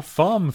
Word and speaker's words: femme: A Femme Fatale. Is that like sexy sheep and horse, isn't it? – No, Femme. That's how you pronounce that word.